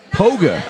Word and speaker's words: Lee Poga: Poga. – Oh, it's okay. – A silent K.